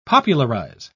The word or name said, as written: popularize